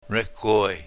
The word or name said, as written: rekwoy